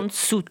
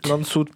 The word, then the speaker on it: Landshut: Il nous reste encore une demi-heure d'émission et on parle de l'amitié franco-allemande avec l'association de jumelage Compiègne Notchot. Comment on prononce exactement? – Landshut. – Landshut, pardon. Landshut.